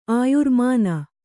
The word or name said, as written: ಆಯುರ್ಮಾನ